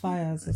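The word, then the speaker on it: Faiyaz: I say Brent Fayez. – Yeah, Fayez.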